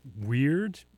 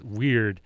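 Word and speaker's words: Wyrd: Following that up was Gauls Weird, I think is how you pronounce it. It's W-Y-R-D. I think it's, I look at it and I look at like, if I have any real nerds in the audience, you might know the name for a dragon that has no wings is a Wyrm. So I'm taking that sort of naming pronunciation kind of thing, and I'm applying it here.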